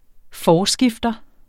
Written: forskifter

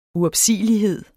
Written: uopsigelighed

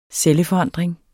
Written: celleforandring

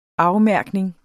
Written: afmærkning

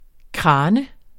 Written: kran

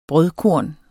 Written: brødkorn

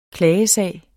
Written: klagesag

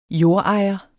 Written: jordejer

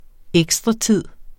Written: ekstratid

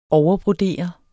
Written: overbrodere

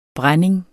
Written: brænding

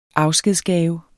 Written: afskedsgave

